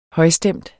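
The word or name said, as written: højstemt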